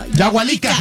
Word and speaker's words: Yahualica: Yahualica, Jalisco.